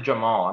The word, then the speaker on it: Jamahal: I think he still pronounced it Jamal. I'm not 100% set on that, but I love this dude though.